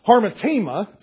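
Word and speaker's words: hamartema: Harmatema, it's H-A-M-A-R-T-E-M-A, harmatema.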